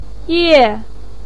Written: ye4

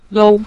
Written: lou5

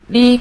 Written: li5

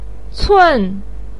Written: cun4

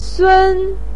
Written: sun1

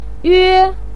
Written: yue1

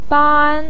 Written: ban1

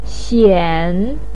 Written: xian3

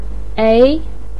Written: ei2

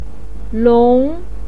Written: long2

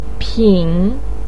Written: pin3

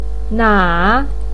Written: na3

